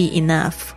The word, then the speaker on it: enough: Let 就让它 it be enough, 够了就可以了 enough. E-N-O-U-G-H 特别注意一下这个 G-H 在这边发音是 F 的声音